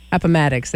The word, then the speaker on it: Appomattox: I wanted to well, we mentioned, I mentioned peace at, I always want to say it wrong, Appomattox, there we go.